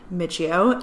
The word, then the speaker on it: Michio: No one ever knows how to pronounce my last name, so just to clarify. I'll take anything, like I'm okay with however you want to say it, but the proper way is Michio, and I'm so excited to be starting this podcast with Danielle.